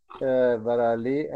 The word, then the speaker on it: Varalli: Well, okay, my name is Massimiliano Varalli. Okay, I know that it's difficult for you probably to pronounce this, but Varalli, and I'm 56.